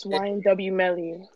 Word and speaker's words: YNW Melly: Millie? – Who's that? – The rapper guy? Did I even say his name right? – It's YNW Melly.